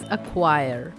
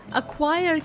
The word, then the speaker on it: acquire: Welcome to this new episode of chalk and duster, and the word of the day is अक्वायर। अक्वायर की spelling है ACQUIRE।